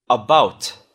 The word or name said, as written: about